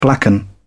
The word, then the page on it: blacken: British English